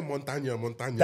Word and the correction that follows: Montoya: That's crazy, bro. It's Montoya. Montoya, por favor, tranquilo, Montoya.